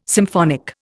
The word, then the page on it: symphonic: Indian